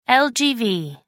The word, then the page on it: lgv: American English